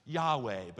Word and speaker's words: Yahweh: We think it's pronounced Yahweh, but as the Jews didn't provide us the original vowels, that's a little bit of a supposition.